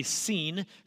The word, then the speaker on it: Sin: And the first three letters of Sinai, S-I-N, pronounced more likely seen.